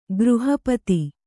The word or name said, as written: ಗ್ರಹಪಥ